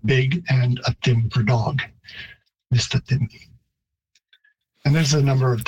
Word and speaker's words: Mistatim: And the Cree stress or pitch system would have a higher pitch on the first syllable, so mistatim.